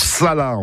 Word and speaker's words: chala: Bref, une précision, en dauphinois, en chala, ça se prononce sala, en fait, sala.